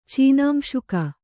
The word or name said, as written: ಚೀನಾಂಶುಕ